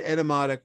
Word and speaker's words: Etymotic: it's e-t-y M O T I C, Etymotic.